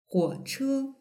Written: huoche

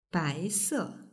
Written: baise

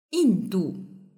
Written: yindu

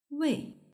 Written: wei

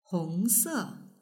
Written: hongse

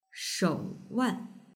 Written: shouwan

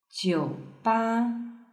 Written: jiuba

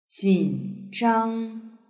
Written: jinzhang

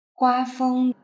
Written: guafeng

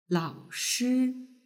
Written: laoshi

